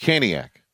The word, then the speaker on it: Caniac: Blake Byram, huge Caniac. Did I say that right? – Yeah, you did the Trip Tracy deal. Huge Caniac.